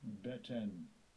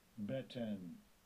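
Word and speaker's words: Beten (בטן): Beten. Beten.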